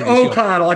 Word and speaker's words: O'Connell: Yeah, it's O'Connell. I think you saying O'Connell, I'm deaf. Yeah, Mike O'Connell.